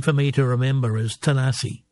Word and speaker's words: Tanasi: The original pronunciation for Tennessee is Tanasi, is where they got the, the anglicized.